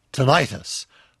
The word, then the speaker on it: tinnitus: But the proper pronunciation is the long I as in tinnitus.